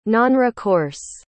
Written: nonrecourse

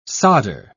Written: solder

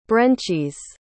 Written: brencis

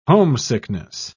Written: homesickness

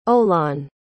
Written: olan

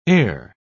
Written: eyre

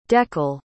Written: dekle

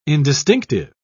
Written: indistinctive

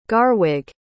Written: garwig

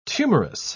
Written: tumorous

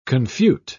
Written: confute

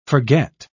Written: forget

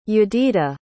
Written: yedidah

